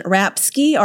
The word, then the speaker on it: Rapske: I'm Roxanne Rapske, R-A-P-S-K-E. My colleague Sarah Wasko, W-A-S-K-O-W.